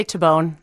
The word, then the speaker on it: Tabone: It's we say Tabone in the U.S. – It would be Tabone.